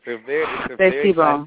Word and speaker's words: C'est si bon: C'est si bon.